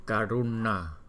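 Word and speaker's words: Karuṇā: Chữ n có dấu nặng là mình đọc là n. Đánh lưỡi lên. Karuna.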